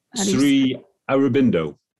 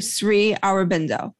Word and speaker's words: Aurobindo: So in your book, um, you quote, I'm going to say this wrong, Sui Sri Oro yeah. – Bendino. – Bendino? – Sri Aurobindo. – Aurobindo. Okay, Sri Aurobindo.